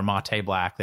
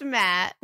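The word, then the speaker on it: matte: or you get brushed nickel or mate black. They have four different colors. And this one... Did I just say mate black wrong? How do you say it? – It's Matt.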